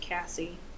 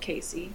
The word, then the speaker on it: Casey: Pamela's older sisters, so Penny and Casey, or sorry, it's pronounced Cassie, spelled Casey, C-A-S-E-Y, but it's pronounced, like, at C-A-S-S-I-E, so I want to say Casey, but it's Cassie.